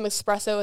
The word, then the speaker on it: espresso: Espresso, I think, right? – Yeah. – I hope I pronounce that right. Okay, good. I feel like so many people pronounce it wrong and it's like infuriating. So hopefully I just got that right.